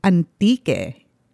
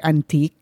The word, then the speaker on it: Antique: Today's trip is a trip to Antique. Antique is spelled like antique, A-N-T-I-Q-U-E, but it's Antique.